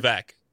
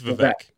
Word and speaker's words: Vivek: Vivek. I believe it's Vivek. – Okay. – Yeah. – Okay. – Is it? – The way that I've heard it is Vivek Ramaswamy.